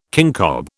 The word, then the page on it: kincob: British English